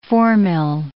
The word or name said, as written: formyl